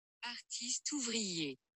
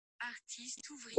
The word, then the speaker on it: Artiste Ouvrier: And then he goes by AO, but his actual pronunciation is Artiste ouvrier. – Artiste ouvrier. Wow. – Artiste ouvrier, basically.